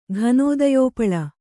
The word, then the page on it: ಘನೋದಯೋಪಳ: kannada